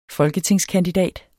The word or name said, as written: folketingskandidat